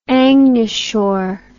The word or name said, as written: angashore